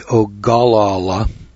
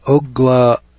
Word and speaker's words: Ogallala: Ogla, that's called the Ogallala, if I can pronounce that, O-G-A-L-L-A-L-A. It doesn't sound this way, but I've heard it pronounced Ogla.